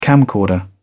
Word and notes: camcorder: British English

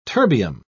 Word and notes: terbium: British English

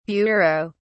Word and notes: bureaux: British English

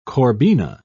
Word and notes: corbina: British English

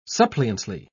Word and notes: suppliantly: British English

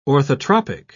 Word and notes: orthotropic: British English

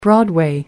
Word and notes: broadway: British English

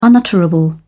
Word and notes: unutterable: British English

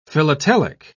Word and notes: philatelic: British English

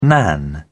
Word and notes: nan: British English

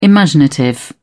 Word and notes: imaginative: British English

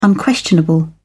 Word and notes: unquestionable: British English

